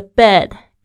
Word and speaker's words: bed: My bed 就是我的床铺，这个 bed b e d 小心一下它的发音哦，跟这个 b a d 的发音是不一样的。